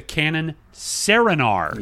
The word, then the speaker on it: Serenar: But then I remember that what Zaire told me was that the best of this lot was the Canon Serenar. – Yes, that's true. – Spelled like serene, but with AR on the end. It's one of those beautiful, like, Japanese camera names.